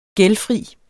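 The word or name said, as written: GÆLDFRIT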